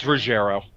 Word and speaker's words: Ruggero: Ruggero Deodato? Deodato? Ruggero? – It's Ruggero.